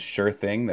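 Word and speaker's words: Schuur Thing: My name is Sure Thing. That's spelled S-C-H-U-U-R Thing.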